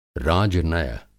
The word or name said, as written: राजनय